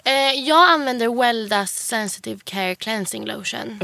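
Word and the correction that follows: Weleda: Ja, Veleda.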